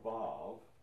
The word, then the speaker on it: vav: The W is pronounced as a V, vav.